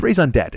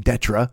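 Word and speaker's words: raison d'être: Cause it's spelled D-E-T-R-E, but it's raison d'etre. It's not fucking detra. There's no way.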